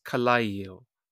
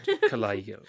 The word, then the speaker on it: Kallayil: I think it's Kallayil. – Kallayil, yes. – Ah, okay, good. Kallayil.